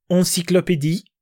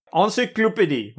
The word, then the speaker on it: Encyclopédie: or in French, I'm going to attempt at a French pronunciation of the original, which is Encyclopédie. – Encyclopédie, oui. – Encyclopédie.